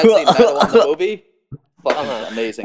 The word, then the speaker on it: Matewan: Madawan, like the where there was the famous coal strike and led to basically like a mini labor war.